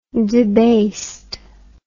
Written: debased

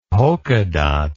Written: polka dot

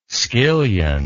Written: skillion

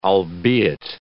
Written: albeit

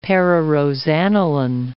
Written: pararosaniline

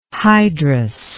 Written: hydrus

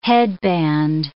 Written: headband

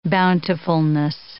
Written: bountifulness

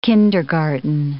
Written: kindergarten

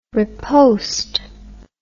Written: repost

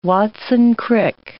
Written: Watson-Crick

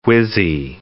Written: whizzy

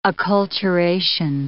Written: acculturation

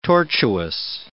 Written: tortuous

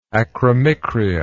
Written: acromicria

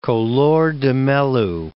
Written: Collor de Mello

